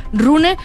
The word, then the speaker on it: Rune: Se pronuncia Rune, le decimos Rune nosotros, pero es Rune.